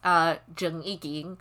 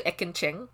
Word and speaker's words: Ekin Cheng: Like my parents pronounced him as Zheng Yiging, but his name is actually Ekin Cheng.